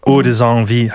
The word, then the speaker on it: Haute Envie: Haute Envie, habe ich es jetzt richtig ausgesprochen? – Ja, weil es ist ein bisschen ein Spielwort. Eine Ode kennt man, eine Ode an das Leben, also mehrere Ode an das Leben macht, aber Haute Envie, dass da Lüste noch dran rauskommt. Ein bisschen in der Betonung.